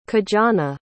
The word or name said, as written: kijana